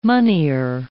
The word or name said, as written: moneyer